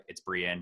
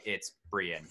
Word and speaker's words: Brion: Like said Brian. I was like, "It's Brion." He's like, "Okay, Brion." And I was like, "It's Brion."